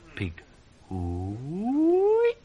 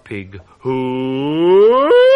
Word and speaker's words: Pig hooey: Not quite right. The first syllable should be short and staccato, the second long and rising into falsetto, high but true. Pig hooey!